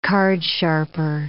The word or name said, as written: cardsharper